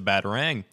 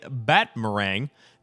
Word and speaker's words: batarang: batarang. And now that I've seen it written down, I realize I've been incorrectly pronouncing it batmerang.